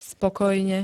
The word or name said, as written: spokojne